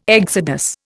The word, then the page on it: exodus: Indian